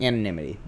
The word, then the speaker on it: anonymity: Anonymity? Mm-hmm. Anonymity. That's it. I just always pronounce it wrong.